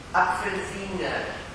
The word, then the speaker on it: Apfelsine: Apfelsine.